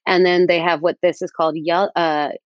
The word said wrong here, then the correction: Yalda: It's called Yelda, not Yadal. Yelda.